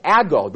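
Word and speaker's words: ago (ἄγω): It's translated from a Greek word ago, A-G-O, long O, ago.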